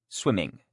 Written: swimming

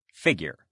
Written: figure

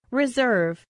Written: reserve

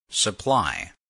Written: supply

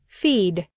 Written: feed

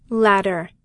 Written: latter

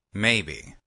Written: maybe

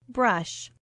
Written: brush

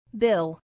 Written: bill

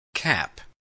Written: cap

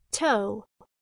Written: toe

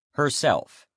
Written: herself

